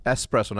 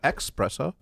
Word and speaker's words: espresso: Espresso, not expresso.